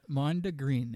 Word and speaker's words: Mondegreen: Like green, like the color? – Yeah, Mondegreen.